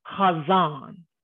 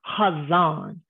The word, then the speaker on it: chazon: It's pronounced chazon, chazon. Okay, chazon is spelled chet, zayin, vav, and then nun.